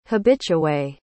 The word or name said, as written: habitué